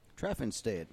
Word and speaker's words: Traffenstead: I'll let you try to pronounce that name. – Traffenstead. – Okay, good job.